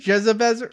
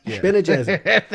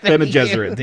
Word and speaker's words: Bene Gesserit: Where do you pronounce it again? – Jezebezeret? – Bene Gesserit. – Bene Gesserit, yeah. – Not Jezebezeret.